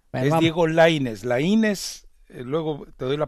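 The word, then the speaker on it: Laínez: Es Lainez con acento en la A, Diego Lainez, no Lainez.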